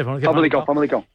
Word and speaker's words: Famalicão: No sé si conoces mucho o poco, pero seguro que algo, a Justin de Haas, que viene de allí, que viene de Famalicão. No sé si lo pronuncio bien. No sé si es Famalicão o Famalicão. – Sí, sí, sí. Famalicão. Haces muy bien.